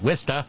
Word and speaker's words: Worcester: A few years back, I learned how to pronounce that. Worcester.